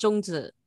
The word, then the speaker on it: zongzi: And then you mentioned mooncakes, you mentioned zongzi, and then on your, if I said that correctly, I'm not too sure. – No, that's close. It's called zhongzi.